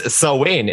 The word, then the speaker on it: Samhain: Saheim is a Gaelic, possibly pagan. No, I'm done. Pagan festival. – Oh no. – It was Samhain is a pagan festival for the end of harvest season.